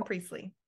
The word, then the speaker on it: Priestley: How's his last name pronounced? – Jason Priestley. – Priestley. Okay. So we had gone in line for Jason Priestley and we were told, hey, come back later.